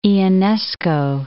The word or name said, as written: Ionesco